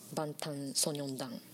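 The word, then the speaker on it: バン タ ン ソ ニョン 団 (방탄소년단): 防弾少年団で、ハングルではバンタンソニョン団。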